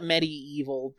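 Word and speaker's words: MediEvil: That's what we're talking about, yeah, Medieval. – Yeah, I call it Medieval because it's spelled weird, but yeah.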